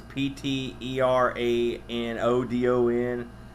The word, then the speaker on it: Pteranodon: Uh, I'm going to try to pronounce this. P T E R A N O D O N. Pteranodon uh, dome, which is like a almost like the like a pterodactyl type thing.